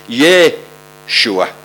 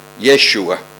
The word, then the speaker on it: Yeshua: And if you go back into the Hebrew, it would be pronounced Yeshua, Yeshua.